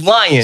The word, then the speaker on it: Leon: Yes, you should spell it Leon, but it's Lion.